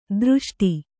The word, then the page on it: ಧೃಷ್ಟೆ: Kannada